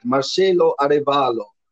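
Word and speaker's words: Marcelo Arévalo: Am I going to say his name right? Marcelo Arevalo. – Good, good. You say it right.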